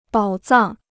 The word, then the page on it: 宝藏: bǎo zàng